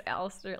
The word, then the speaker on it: Alistair: Just Alistair, like Alistair Black.